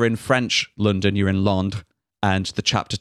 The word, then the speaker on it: Londres: If you're in the French version, it's spelled the French way and pronounced the French way, which I'm not going to try because I will get it wrong. L-O-N-D-R-E-S. – Londres.